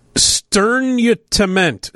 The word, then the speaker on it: sternutament: Sternutament. Sternutament. Sternutament. Sternutament. That's a S-T-E-R-N-U-T-A-M-E-N-T.